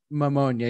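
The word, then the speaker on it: Mamunia: I never know how to pronounce this, but Mammonia, I think it's how you say it. It sounds like pneumonia, but it's Mammonia.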